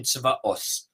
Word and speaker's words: Tzvaot: Okay, and what these all of this do is they explore a specific name of God, which is called Tzvaot, Ashkenazic pronunciation, Tzvaot.